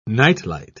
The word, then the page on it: night-light: British English